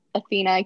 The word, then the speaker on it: Athena: Athena K.